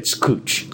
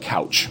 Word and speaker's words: Couch: So this one I learned a long time ago, but it's pronounced cooch. – Cooch. It looks like couch. – Right. – And a lot of people say couch. Most people learn pretty quick.